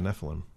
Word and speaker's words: Nephilim: How do you pronounce that? N-E-P-H-I-L-I-M. – Yeah, Nephilim. Well, I played enough Diablo to know it's pronounced Nephilim.